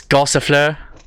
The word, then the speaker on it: Gossifleur: Gossifleur. – Yeah, okay. Yes, Gossifleur.